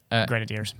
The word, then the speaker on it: Grenadiers: Another story, one of the mountaintop finishes, Ineos Grandos... – Grenadiers. – Yeah, I butchered it.